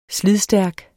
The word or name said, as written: SLIDDET